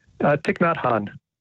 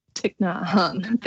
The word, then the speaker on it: Thich Nhat Hanh: How do I say his name again? I don't want to butcher it. – Thich Nhat Hanh. – Thich Nhat Hanh. Thank you.